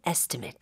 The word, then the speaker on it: estimate: estimate이라고 하는 것은 견적을 명사로 나타낸 건데 그때 발음할 때 made이 아니었죠. – 맞아요. estimate. – Uh-huh. – 그래서 방문 견적은 in-home estimate.